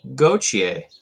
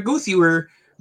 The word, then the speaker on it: Gauthier: We're going to talk about Cutter Gauthier. Mm-hmm. Huh? Listen to that pronunciation. Yeah, I really wanted to say cuter, goothier, but, you know, I put that aside just for you, buddy.